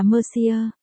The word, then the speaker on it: Mercier: Chú thích, 1, nguyên văn là Mercier, đọc là Mercier.